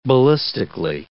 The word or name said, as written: ballistically